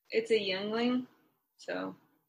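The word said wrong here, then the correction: Yuengling: Youngling or Yingling? – I don't know how to pronounce it. – Pretty sure it's Yingling. – It is.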